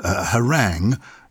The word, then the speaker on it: harangue: And an interesting pronunciation again, because harangue, it's spelt A-N-G-U-E at the end, like meringue, but ague A-G-U-E would be pronounced ague.